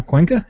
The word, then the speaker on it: Cuenca: Cuenca, is that correct? – That is correct.